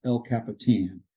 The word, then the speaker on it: El Capitan: So, and it's actually pronounced El Capitan.